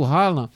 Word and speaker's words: Haaland: Um cara desse, o Haaland, não é? Eu pronunciei certo, né? Haaland, né? – Isso. – Pronto. O Haaland, ele é um cara que, não é, também tem uma inteligência fantástica para movimentação.